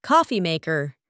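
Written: coffee maker

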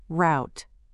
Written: route

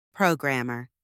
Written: programmer

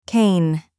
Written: cane

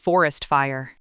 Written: forest fire

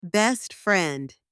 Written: best friend

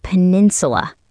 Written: peninsula

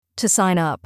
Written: to sign up

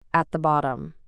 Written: at the bottom

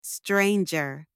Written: stranger

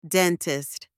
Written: dentist

